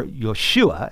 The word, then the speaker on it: Yeshua: But the real beauty of this name change is that Joshua, or Yeshua, as the Hebrews would pronounce it, is the equivalent of Jesus in the Greek.